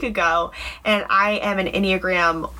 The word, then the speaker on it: Enneagram: Because according to, let's do the Enneagram first. Enneagram? – I like that. – Yes. Yeah, that's how you say it.